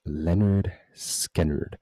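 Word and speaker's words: Lynyrd Skynyrd: Yes, it's pronounced Leonard Skinner.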